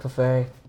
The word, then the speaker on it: Preferi: Yeah, and your last name begin with a P. I can't pronounce it. – Yeah, nobody can, but it's it's pronounced Preferi.